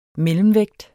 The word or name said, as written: mellemvægt